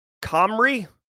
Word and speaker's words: Cymru: CYMRU is pronounced Comrie.